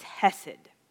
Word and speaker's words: hesed: If you look at that word mercy, in the Hebrew, it's hesed, is how it's pronounced.